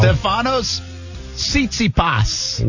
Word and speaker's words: Stefanos Tsitsipas: Stefanos Tsitsipas.